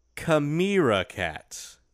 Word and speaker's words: chimera: So that's C-H-I-M-E-R-A. – Mm-hmm. Because a chimera is a mythical beast. – I thought you would pronounce it Chimera. – Chimera. – Because that's the main way that they pronounce it in America.